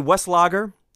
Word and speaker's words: Weslager: C.A. Westlager, and I believe I said his name right this time,